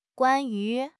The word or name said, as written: guan1 yu2